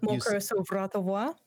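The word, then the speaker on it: Mon cœur s'ouvre à ta voix: Mon Coerzo. I'm going to screw it up. – Mon Coerzo Vratovoi.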